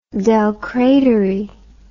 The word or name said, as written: del credere